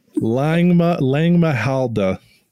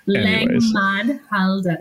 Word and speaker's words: Langmahdhalde: Langmahdhalde. That still did it wrong. – Langmahdhalde.